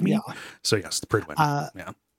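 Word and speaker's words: Prydwen: the Pridwin, which you said Pridewood earlier, which makes more sense. They pronounce it Pridwin, which is really strange.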